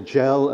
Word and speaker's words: Gell: I'll concentrate first on these two characters, William Gell, or Gell, as I think I should pronounce it, William Gell and Edward Dodwell, who were out in Athens in 1801.